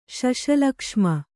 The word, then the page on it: ಶಶಲಕ್ಷ್ಮ: kannada